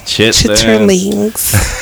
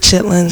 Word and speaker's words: chitterlings: That's chitterlings. What chitterlings? – That's how it's spelled. – That's how it's spelled. It's and like, but black people normally pronounce it chitlins.